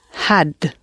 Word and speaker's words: had: Yes, if you took the English word had, like I had a coffee this morning, and you compressed it and put the breathy h sound in the beginning, had.